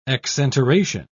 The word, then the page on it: exenteration: British English